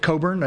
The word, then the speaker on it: Cockburn: He pronounced it Cockburn, as I found out from my British colleagues when I presented the same lecture at the University of London.